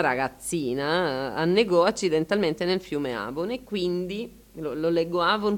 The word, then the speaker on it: Avon: Si dice Avon, ci sono anche stato di persona, si vede Stratford up of Avon.